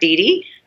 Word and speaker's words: DeDe: So it's WEDE, which is like DeDe.